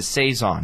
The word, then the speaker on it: Saison: It's a Saison, not Saison. I've been saying it wrong, and I admit that.